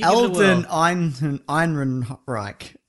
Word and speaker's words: Ehrenreich: Alden Ehrenreich.